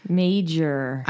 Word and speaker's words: Meijer: M-E-I-J-E-R. – Major. – I'm saying Major because I feel like it's probably not a silent J or like a H, right? – Yeah.